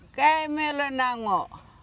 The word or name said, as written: gayimilinnhangoo